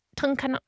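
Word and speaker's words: Tunkhannock: Tony Masala from Tunkanuk. – It rhymes with mechanic.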